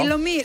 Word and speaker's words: Ilomilo: Ilomilo.